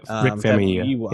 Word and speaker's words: Famuyiwa: I want to make sure I'm saying his name right. Sorry. It's Rick Famuyiwa. – Yeah, Rick Famuyiwa. Yep.